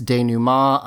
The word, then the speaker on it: denouement: Adrian points out it's denouement. I'm sorry. I didn't say it right.